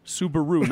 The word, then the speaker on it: Subaru: Subaru, not super. Subaru.